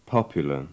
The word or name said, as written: popular